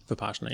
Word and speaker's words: Vipassana: which is the Vipassana meditation for the last 6 days of the 10-day course. – Is that how you pronounce it, with the Pashana? – Vipassana, yeah.